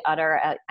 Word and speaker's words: Utter: It's Emily Utter. I know you'll probably link it, or at least they can see the actual spelling of my name. It's not spelled like a cow's udder.